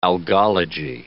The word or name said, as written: algology